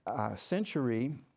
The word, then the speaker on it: Cenchreae: And at Cenchreae, is actually how it's pronounced, he had cut his hair, for he was under a vow.